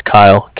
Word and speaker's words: Kyle: It's Kyle, K-Y-L-E, calling with the Bank of Cardiff.